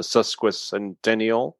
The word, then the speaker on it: sesquicentennial: A sesquicentennial.